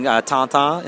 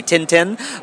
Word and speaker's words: Tintin: Some of my earliest memories of reading are with my mom reading Tintin. In America we say Tintin, but okay. But my mother pronounced it with the French pronunciation Tintin, and reading classic Marvel titles with my dad.